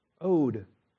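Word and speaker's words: ode (ᾠδή): The spiritual songs are the pneumatikē ode, O-D-E, ode. In Greek, it's O-D-E, ode, with a long O and a long E.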